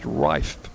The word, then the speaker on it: Riffe: Reif spells his name R-I-F-F-E, pronounced Reif.